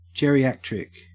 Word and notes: geriatric: Australian English

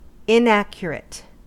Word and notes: inaccurate: American English

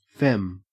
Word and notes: fem: Australian English

